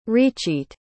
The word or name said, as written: recheat